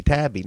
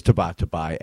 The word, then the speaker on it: Tabatabai: I mean, because I used to call him John Tabby. Now, I knew it wasn't the right way to pronounce his name. I think I did know that. But it's such a great way to, you know, T-A-B-A-T-A-B-A-I, John Tabby. But it is Tabatabai, and John's such a good sport about it that he does say I ruined his name in poker forever.